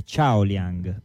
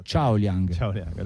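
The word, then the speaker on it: Qiao Liang: Correzioni di pronuncia, la Q in cinese si legge come la C dolce, quindi è Ciao Liang. – Esatto. – Ciao Liang.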